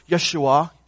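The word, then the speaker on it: Yeshua (ישוע): a man whose name in the Hebrew would be pronounced Yeshua, Joshua, but whose name in the Greek would be pronounced what?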